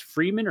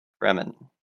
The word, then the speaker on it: Fremen: Is it pronounced Freeman or Fremen? – Fremen, yeah. – Fremen, okay.